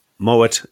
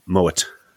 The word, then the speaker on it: Moët: So I believe, I'm open to correction, but I believe it's Moët and Chandon. – Yes, it is actually pronounced the T. – Pronounce the T. So if it was French origin, you wouldn't pronounce the T. So although it's a French company, they were Dutch originally. The family were Dutch, and therefore it's pronounced Moët.